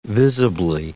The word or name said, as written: visibly